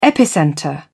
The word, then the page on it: epicentre: British English